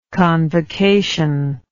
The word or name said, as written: convocationally